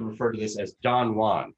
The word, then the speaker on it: Juan: And then jump ahead a few lines, that phrase, a new one, rhymes with, I'll therefore take our ancient friend Don Juan.